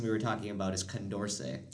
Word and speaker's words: Condorcet: I kept talking about, what did I say, concordant voting or something? – I think so. – Yeah, just wrong. It's not pronounced that way. It's not spelled that way. It's C-O-N-D-O-R-C-E-T, which is Condorcet.